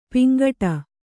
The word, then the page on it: ಪಿಂಗಡೆ: kannada